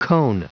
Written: cone

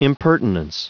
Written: impertinence

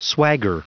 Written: swagger

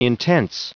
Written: intense

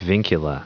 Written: vincula

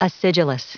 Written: acidulous

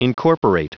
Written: incorporate

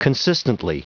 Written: consistently

